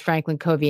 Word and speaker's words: Franklin Covey: I mispronounced it, by the way. It is Franklin Covey,